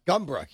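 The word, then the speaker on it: Gumboro: Millville's down near Gumbra. – Yeah. – Gumbra. You said it properly. Good job.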